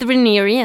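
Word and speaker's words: Raniere: Cuando Ranieri, Keith Ranieri, así se pronuncia, eh, tenía 24 años, tuvo una relación con una chavita de 15 años mm.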